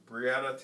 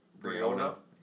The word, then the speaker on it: Breonna: Like, one, Breonna Taylor, I believe that's her name, how it's pronounced. Breonna.